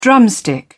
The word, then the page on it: drumstick: British English